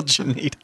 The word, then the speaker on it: Janita: for real Janita. – Okay. – I'm not mispronouncing the J. Okay. There's a G.